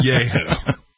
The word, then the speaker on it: Yahoo: I believe it's pronounced Yahoo. – Yes, Yahoo.